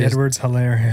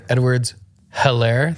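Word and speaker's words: Edwards-Hilaire: Clyde Edwards Hilaire. – Yeah, they say Edwards Hilaire. They say Edwards Hilaire. Yeah, like they like French. Accent it.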